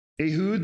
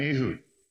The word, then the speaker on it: Ehud: That's how you say it. Ehud, the son of Gera, the Benjaminite. A left-handed man. Okay, so here's what we know. There's this guy named Ehud.